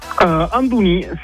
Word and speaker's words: Andouni: Comment on prononce Andouni, c'est comme Antonin avec un D.